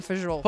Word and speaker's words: fagioli: The pasta fagioli soup, which is am I saying that right? Fagioli? – Yes, pasta fagioli.